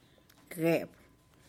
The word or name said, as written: geb